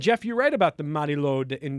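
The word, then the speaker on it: Mari Lwyd: And she's lately been very focused on Welsh folklore, King Arthur, and also the Mary Lord.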